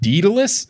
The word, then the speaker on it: Daedalus: Feels like the other one could go 50-50 because it's spelled D-A-E-D-A-L-U-S. But Daedalus? No, that's incorrect. I will be okay with the other two. I will be saying Daedalus because that's how they say it in Hades a video game, and that is the most canon thing of all.